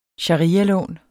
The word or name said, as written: sharialån